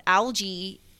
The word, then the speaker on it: algae: Also, I found out that in Australia, they pronounce algae. – Mm-hmm. Yeah. – Who knew? – Hard wow. G. Hard G on that one.